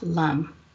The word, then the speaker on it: lam: So the mantra for the root chakra is L-A-M, and it's pronounced LUM. During meditation, it's drawn out like this. Let me get my voice together. LUM.